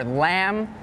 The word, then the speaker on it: lamb: And some examples with a silent B are lamb, thumb, and doubt.